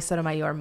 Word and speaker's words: Sotomayor: Grace Vonnie Sotomayor Montri.